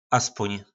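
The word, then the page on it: aspon: nářečí Po našimu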